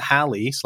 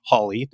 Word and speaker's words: Halley: Either way, there was a miscalculation, and Edmund Halley / Holly, depending on your pronunciation,